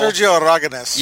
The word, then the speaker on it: Sergio Aragonés: Sergio Aragonés.